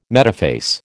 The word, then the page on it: metaphase: American English